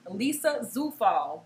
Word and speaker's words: Zufall: Allow me to introduce my special guest with a warm welcome, Lisa Zufall.